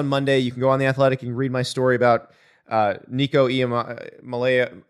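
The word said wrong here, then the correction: Iamaleava: Nico himself told me how to pronounce Iamalava. – Iamalava. Iamalava. I've got it now.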